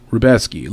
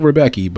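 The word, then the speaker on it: Rębecki: Pronounced Rubeski. Looks like Rebecky, but it's Rubeski.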